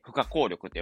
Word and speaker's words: 不可抗力: で、その中の、えー、不可抗力って曲なんですけども、えー、負荷、負荷、負荷が、えー、可能負荷の負荷で、えー、効力が、えー、抗う力って書いて、負荷効力って読むんですね。